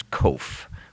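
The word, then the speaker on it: Qof: But Kof is actually, when we would write it in English, would probably be more like a K or a CH, but it's Kof.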